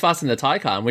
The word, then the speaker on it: Taycan: Taycan. Is that how you say it? – Taycan, yeah. Taycan? – No, I think it's Taycan.